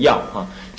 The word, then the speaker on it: 乐: 要就是乐，信你们是念乐是吧？你们是念乐还是要？快快乐的乐要念成要啊！